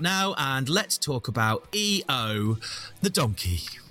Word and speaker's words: EO: EO, which I believe is still pronounced EO in its native Polish, but is spelt IO in Polish.